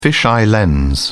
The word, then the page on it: fisheye lens: British English